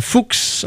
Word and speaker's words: Fuchs: On va écouter un petit peu de rock and roll maintenant avec Miss Dana Fuchs. Hein, ça se prononce Fuchs, ça s'écrit F-U-C-H-S.